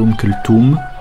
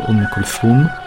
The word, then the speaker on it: Oum Kalsoum: Les Marocains prononceront plus volontiers Oum Kalsoum. Les Algériens, selon la région, seront Oum Kalsoum, Oum Kalsoum. Les Tunisiens prononcent l'interdental, donc ce sera Oum Kalsoum.